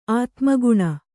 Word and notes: ಆತ್ಮಗುಣ: ātmaguṇa

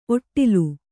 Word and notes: ಔಡಲ: auḍala

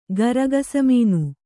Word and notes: ಗರಗಸಮೀನು: garagasa mīnu

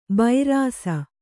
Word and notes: ಬೈರಾಸ: kannada

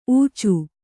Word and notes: ಊಚು: ūcu

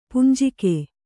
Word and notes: ಪುಂಜಿಗೆ: kannada

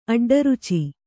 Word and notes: ಅಡ್ಡರುಚಿ: aḍḍaruci

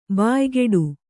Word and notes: ಬಾಯ್ಗೂಡು: bāygūḍu

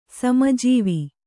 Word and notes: ಸಮಜೀವಿ: Kannada

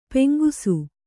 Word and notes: ಪೆಂಗುಸು: peŋgusu

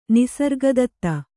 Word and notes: ನಿಸರ್ಗದತ್ತ: nisarga datta

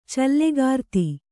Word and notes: ಚಲ್ಲಕಾರ್ತಿ: callakārti

